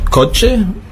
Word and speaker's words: coche: Wir hatten gestern die Aussprache der Buchstaben Ñe für mañana, das Doppel-L für llevar, wird ausgesprochen wie ein J, das CH, coche, wird ausgesprochen, ja, wie ein TSCH im Deutschen.